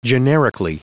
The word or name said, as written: generically